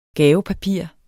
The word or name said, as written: gavepapir